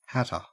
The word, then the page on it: hatter: Australian English